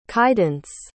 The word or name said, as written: kaidence